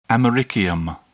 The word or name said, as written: americium